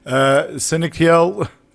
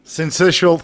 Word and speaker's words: Syncytial: You may have noticed, and I'm calling it RSV because I keep getting the middle name wrong in the pronunciation. Let me give it a try again. Respiratory. This is where the dyslexic brain kicks in. Syncytial. – Syncytial. – Syncytial, thank you.